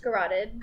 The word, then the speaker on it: garrote: He had an unusual, how do you pronounce that word? Garrote? Garrote? – Garroted. – Garrote. – When you like choke somebody like that, they're garroted. – So it's garrote. – Yes.